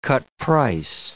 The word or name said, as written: cut-price